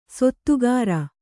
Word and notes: ಸೊತ್ತುಗಾರ: sottugāra

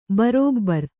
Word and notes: ಭರಭರ: Kannada

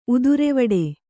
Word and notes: ಉದುರೆವಡೆ: kannada

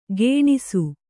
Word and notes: ಗೇಣಿಸು: gēṇisu

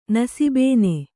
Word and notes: ನಸಿಬೇನೆ: Kannada

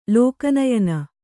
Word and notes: ಲೋಕನಯನ: Kannada

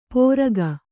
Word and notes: ಪೋರಗ: pōraga; Kannada

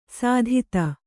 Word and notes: ಸುದತಿ: Kannada

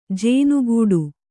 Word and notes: ಜೇನುಗೂಡು: jēnugūḍu

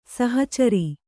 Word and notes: ಸಹಚರ: sahacara

